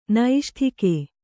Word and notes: ನೈಷ್ಠಿಕ: Kannada; naiṣṭhika

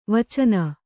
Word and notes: ವಾಚನೆ: vācane

